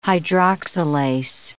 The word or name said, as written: hydroxylase